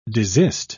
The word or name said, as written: desist